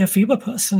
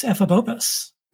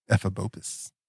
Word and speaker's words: Ephebopus: Like I used to say Ephebopus, and it's Ephebopus. – Ephebopus.